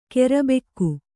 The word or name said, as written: ಕರ್ಬಕ್ಕಿ